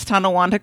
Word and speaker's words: Tonawanda: Andrew did pronounce Tonawanda correctly.